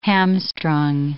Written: hamstrung

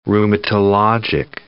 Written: rheumatologic

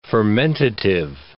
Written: fermentative

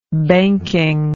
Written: banking

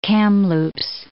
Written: Kamloops

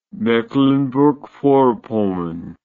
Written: mecklenburg vorpommern

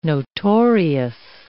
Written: notorious

